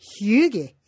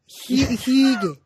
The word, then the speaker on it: hygge: the Danish art of hygge, hygge, hygge.